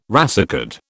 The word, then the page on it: racecard: British English